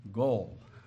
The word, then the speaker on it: goel: And the way it is spelled in English is G-O-E-L, Goel, goal.